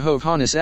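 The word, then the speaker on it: Hovhaness: Some years later, deciding to re-establish his Armenian ties, he changed the spelling to Hovhannes accent on the second syllable.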